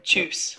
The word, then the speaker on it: Tschüss: Tschüss. – Yeah. Tschüss.